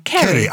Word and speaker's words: Ceri: Is that C-E-R-I? Kerry. – I think it'll be pronounced Kerry.